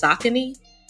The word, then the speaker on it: Saucony: We say Saucony, but apparently it's Saucony.